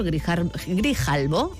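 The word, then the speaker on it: Grijalbo: novela editada por Grijalvo, ahora sí lo he dicho bien, y escrita por Jorge Molist.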